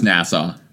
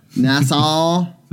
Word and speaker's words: Nassau: Because everyone's like, oh, you're going to Nassau? I think that's how you say it. – Just Nassau. – Nassau. I don't know why there's... You don't have to elongate the last vowel.